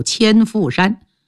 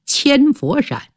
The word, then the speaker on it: 千佛山: 斧呢就是避祸的意思，因为发洪水，人们常到山上避难居住，所以给他取个名字叫千斧山。到后来这个斧就变成佛了，因为当地人口音呢，把佛读成斧。搬迁的迁也变成了千万的千，这千斧山就成了现在济南的千佛山。